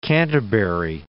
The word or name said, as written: Canterbury